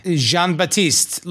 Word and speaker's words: Jean Baptiste: Jean Baptiste, like the artist.